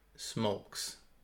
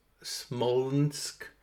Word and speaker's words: Smolensk: I can't even pronounce it. Smolensk. Smolensk. Sounds like smokes, but not quite.